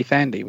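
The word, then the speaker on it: Thandie: Tandy Newton. – No, it's Tandy Thandy. – Tandy Thandy. Newton is pronounced Thandy.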